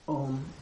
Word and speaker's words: Om: Om is the A-U-M, Om, and it stands really for, we'll do that maybe in a satsang. Take the word Om as a nama, a sacred nama for the entire creation, for Ishwara that has its being in Brahman.